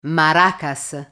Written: maracas

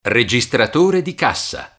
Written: registratore di cassa

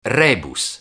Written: rebus

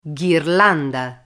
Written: ghirlanda

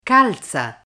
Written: calza

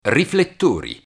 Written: riflettori

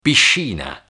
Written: piscina

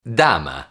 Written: dama

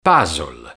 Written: puzzle

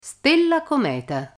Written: stella cometa

